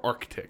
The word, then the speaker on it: Arctic: Do you say the word A-R-C-T-I-C, Arctic or Arctic? – It's Arctic.